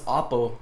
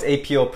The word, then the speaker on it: APO: A lot of people ask me that because they would pronounce it like, what's Oppo? And I'm like, ah, we're not playing baseball, you know? It's not Oppo. And I was like, no, it's APO Preps, and it stands for Adjust, Persevere, and Overcome.